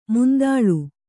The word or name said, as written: ಮಂತುಳ್ಳಿ